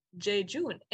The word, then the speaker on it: jejune: That is how you pronounce it. Jejune.